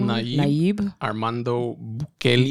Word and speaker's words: Nayib Armando Bukele: Nayib Armando Bukele.